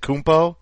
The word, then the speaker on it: Antetokounmpo: Is it Kumpo? – It's Kumpo.